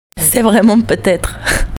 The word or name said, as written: vraiment peut etre